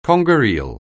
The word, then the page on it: conger (eel): anglais